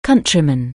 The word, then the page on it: countryman: anglais